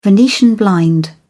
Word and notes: venetian blind: British English

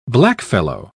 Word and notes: blackfellow: British English

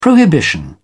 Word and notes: prohibition: British English

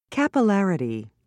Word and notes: capillarity: British English

